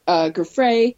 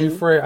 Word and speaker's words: Giuffre: He just mentioned Virginia Giuffre. Tell me how to say her name again. – Giuffre. I think it's Giuffre. That's what I think. So it's hard. – Giuffre. – G-I-U-F-F-R-E.